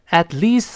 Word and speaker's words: at least: at least three times. At least 注意发长音 e，不要读 at least。